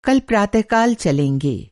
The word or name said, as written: प्रातःकाल